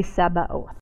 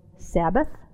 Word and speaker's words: Sabaoth: I've never heard anyone ever minister on Yahweh Sabaoth. I think I spent a lot of years mispronouncing it as Yahweh Sabbath. Looks kind of like that, you know.